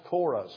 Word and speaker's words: Korah: There's Cain, and there's Balaam, and there's Korah. Spelt here C-U-R-E. It's pronounced Korah.